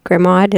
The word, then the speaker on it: Grimaud: Grimaud is how you, Grimaud is how it's spelled, but Grimaud is how you say it.